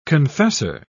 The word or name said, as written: confessor